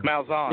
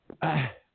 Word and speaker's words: Malzahn: Malzahn. – Yeah. – Malzahn.